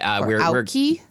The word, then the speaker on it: Aoki: I don't know if I'm pronouncing it right. – Watzel said that Aoki? – Yes, Sadaharu Aoki.